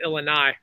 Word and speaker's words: Illini: Everyone laughs at me how I pronounce it, but I pronounce it Illini.